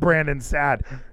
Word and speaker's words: Brandon Saad: Brandon Saad. How do you pronounce that name? – I think you hit it. – Brandon Saad.